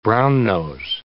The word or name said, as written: brownnose